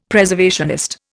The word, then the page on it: Preservationist: Indian